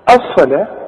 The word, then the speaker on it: الصلاة: الصلاة مكتوبة بالواو لكن تنطق بالالف، شوف الصلاة، واخد بالك.